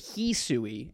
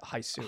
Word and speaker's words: Hisui: The first trailer pronounced it He Sui, and then they sort of backed off to Hisui.